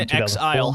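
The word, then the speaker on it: inXile: Is that how you pronounce it, or is it supposed to be in file? Whatever. Uh, came in, in exile. How about that? in exile. – That probably makes sense.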